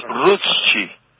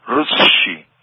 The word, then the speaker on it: Rutschi: Se mezcla la U con la E, es Rutschi. Rutschi. Pero ahí no es Hi, en otros casos el alemán dice Hi, pero es suizo, es de Zurich.